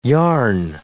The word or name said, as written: yarn